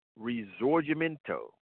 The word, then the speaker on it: Risorgimento: Risorgimento. And that's how you pronounce it, because I'm listening to the lady tell me how to pronounce it. It's spelled R-I-S-O-R-G-I-M-E-N-T-O.